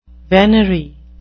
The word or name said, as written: venery